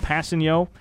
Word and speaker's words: Kpassagnon: I mean, Passanio, for those of you who haven't seen his name in writing yet, his last name, pronounced Passanio, K-P-A-S-S-A-G-N-O-N. – Passanio.